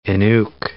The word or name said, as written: Inuk